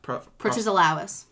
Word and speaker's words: Protesilaus: Protesilaus. – Protesilaus.